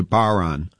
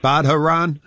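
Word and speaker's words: bodhrán: the Irish drum is a baron, and it's pronounced, or it's spelled BODHRAN. So people are always saying, you playing that Badharan?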